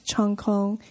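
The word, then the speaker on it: Cheung Kong: Professor Gordon is also a visiting professor of economics at Chung Kong Graduate School of Business. I'm not sure if I'm saying the name right. – That's correct. – That's a Cantonese pronunciation.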